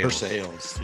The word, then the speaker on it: Versailles: That's how you say it, right? Versailles. – Versailles, yeah. – Even though you're supposed to say Versailles if you're somewhat educated, but it's Kentucky, so it's Versailles. Yeah, I know.